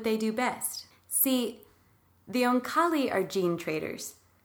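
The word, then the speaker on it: Oankali: See, these aliens, the Onkali, you good with that pronunciation? – That's exactly how I do it.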